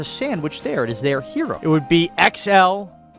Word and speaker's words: gyro: It's pronounced the way it's spelled. It's gyro. Enough with the fancy schmancy. We're not in Greece. – It's properly pronounced hero.